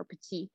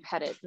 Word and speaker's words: Pettit: The first one was in 2005, a case called Pettit, or Petit, but everybody's been pronouncing it Pettit in the English-speaking Canada.